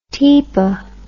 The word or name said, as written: tepa